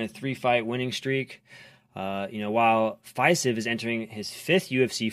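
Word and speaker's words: Fiziev: Viziev. Is that how you pronounce it? Viziev? – Viziev.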